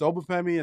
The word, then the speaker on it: Obafemi: You said, hey, you can call me Femi, but it's Obafemi.